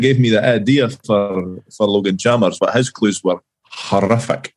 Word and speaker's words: Logan Chalmers: This player's last goal for us was in a 6-0 win against Morton, and his name rhymes with Brogan Clammers. – Logan Chalmers, eh? – There we go.